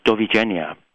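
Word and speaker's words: Dovidenia: Goodbye, meanwhile, is Dovigenia.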